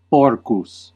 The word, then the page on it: hog: American English